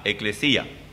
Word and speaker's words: eklesia: Se escribe E-K-L-E-S-I-A, eclesía, que significa llamar fuera.